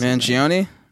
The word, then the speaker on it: Mangione: Chuck Mangoin. – Wait, pass him more. I can't. He looks like Jimi Hendrix in a hat. – Chuck Man, what? How the hell do you pronounce Mangione? – Mangione.